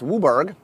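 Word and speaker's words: WUBRG: It costs Wooburg, white, blue, black, red, green. So Wooburg is how we pronounce.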